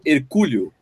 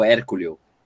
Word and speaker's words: hercúleo: Então você pode falar aí pra gente onde você está assistindo, que vai fazer aquele esforço hercúleo, hercúleo, que fala o Hérculeo, como é que fala isso? Não, Hercúleo, né, pô? Hercúleo.